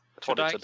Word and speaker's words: Tudyk: Tudyk, tror jeg. Tudyk, ja.